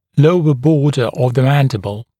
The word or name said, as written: lower border of the mandible